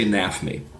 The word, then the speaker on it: NAfME: They pronounce the F, though, so they say NAFME.